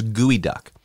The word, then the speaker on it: geoduck: So it's spelled G-E-O duck. So you'd think it's geoduck, but it's gooey duck.